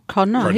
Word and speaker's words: Cardinal: Cardinal.